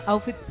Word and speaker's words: outfit: Eso te iba a decir, y además entre ruedas, entre licras, claro, entre gafas chivas, de todo, porque también los outfits son muy bonitos y son parte de todo esto. – ¿Los qué? – Outfit. ¿Verdad, Tati? Lo dije bien, Tati, es que me está molestando. – Exacto.